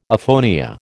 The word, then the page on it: aphonia: American English